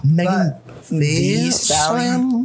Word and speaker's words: Megan Thee Stallion: It's actually Megan Thee Stallion. – Dude, there's two E's in it. Okay, so if you're gonna say her name, put some respect on it. – Yeah, exactly. – Or not. – Gotta pronounce the.